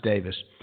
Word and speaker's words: Davies: And his name in England is pronounced Davis. For you Americans, that's Ray Davies of the Kinks.